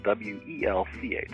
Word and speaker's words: Welch: And my last name is spelled W E L C H, so it's Douglas E.